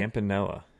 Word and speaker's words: Campanella: Campanella.